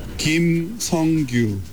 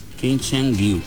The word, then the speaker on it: Kim Sung-gyu: ¿tú me puedes decir cómo se pronuncian los nombres de vuestros futbolistas? – I have a problem. I'm Japanese, Spain. I need a pronunciation de players Corea. – Kim Sung-gyu. – Kim Sung-gyu.